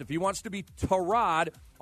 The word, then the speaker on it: Tyrod: Well, it turns out everybody's been pronouncing Tyrod's name the wrong way. It is Tyrod. According to Joel Batonio, he told a guy, Adidas something with a check mark at the end of his name, that Tyrod is actually pronounced Tyrod, and that Tyrod has been too nice to correct people. So apparently his name is Tyrod and not Tyrod.